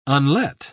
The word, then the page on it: unlet: British English